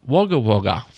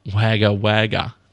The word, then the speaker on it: Wagga Wagga: including Peter Clivereaux from the very carefully pronounced Suffolk and Cool. Wagga Wagga, not Wagga Wagga.